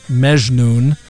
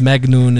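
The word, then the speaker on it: Magnoon (مجنون): In Egyptian Arabic, it'd normally be Mejnoon, but Magnoon is like the Egyptian pronunciation.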